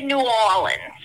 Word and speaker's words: New Orleans: I'm not sure, did I pronounce New Orleans correctly, or is it New Orleans? – We say New Orleans. That's how you pronounce it down here. That's how you know you have somebody that's truly from down here in southern Louisiana, is when they say New Orleans.